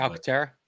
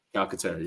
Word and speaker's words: Calcaterra: you got uh, I can I can't pronounce the name the way y'all pronounce it. Calcaterra. – Calcaterra, yeah. Um, I mean, those guys right now, you know, they're they're hungry to go ahead and get that position as well.